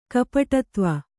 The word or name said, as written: ಗೋಪಟುತ್ವ